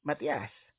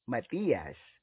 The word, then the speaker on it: Matias: It is pronounced as Matias. Foreigners usually pronounce it with a long I, Matias. Whether you pronounce it with short or long I, it doesn't really matter because I shall respond to you.